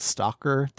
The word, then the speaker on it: S.T.A.L.K.E.R.: There is a film in 1979 called Stalker that the brothers wrote the screenplay for. – Yeah. – There is a series of games called, let me make sure I'm pronouncing this right, S-T-A-L-K-E-R.